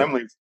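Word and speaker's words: Laemmle's: I saw it in Lemley's, or however you pronounce that theater. – Lemley's, right. – Lemley's.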